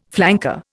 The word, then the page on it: flanker: Indian